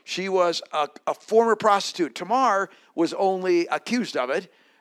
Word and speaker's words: Tamar: Now, all Hebrew words have the emphasis on the last syllable. So in Hebrew, and I'm not great at Hebrew, but I think this would be pronounced Tamar at the end. We kind of would say Tamer, putting the emphasis on the first syllable.